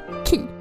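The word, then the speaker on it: Key (χ): Det är bara det faktum att en heter i text, det är skrivet Kingdom Hearts X, men det uttalas Key.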